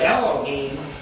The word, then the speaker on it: Elohim: The word there is Elohim, E-L-O-H-I-M, Elohim.